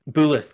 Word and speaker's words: Boulis: Boulis.